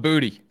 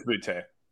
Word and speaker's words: Boutte: Nessler was calling him Booty. – Booty, Bowdy, whatever. God, there's so many Booty guys I think it's Boutte.